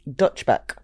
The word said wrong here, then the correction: Dutch Pot: Dutch pot. Like Netherlands. Netherlands. – I'm going to get you to spell it for the viewers. – D-U-T-C-H-P-O-T.